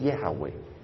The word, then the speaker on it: Yahweh: Como dice Gail Erwin, él cree que la pronunciación, nadie sabe realmente, pero Gail piensa que la pronunciación más correcta es Yahweh.